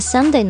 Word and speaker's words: sundae: 是更像是一个 Family Day 是为了让你能够跟家人啊朋友一起分享这一道夏日小甜品的日子了那说到这个热巧克力冰淇淋圣诞我们刚讲到的这个 Sunday 它的名字其实呢在背后也是有一个非常有趣的小故事的这个 Sunday 呢就是发音听上去和星期天是一模一样的嘛但是呢它是 S-U-N-D-A-E